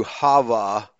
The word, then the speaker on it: Hava: And probably Ava Gardner is someone who has the correct or the closest pronunciation to Hava as a personal name.